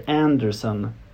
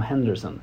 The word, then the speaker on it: Henderson: Så det jag reagerar mest på efterhand, efter att ha sett liksom allting, det är ju att han säger Anderson om Henderson. Eller uttalar Henderson som Anderson.